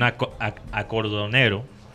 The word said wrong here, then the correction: acordeonero: Lo dije bien. Acordeonero. Acordeonero, sí.